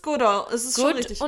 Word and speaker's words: Goodall: Goodall. Goodall.